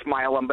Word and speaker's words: Milam: It's Milam, but everybody.